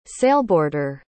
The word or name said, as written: sailboarder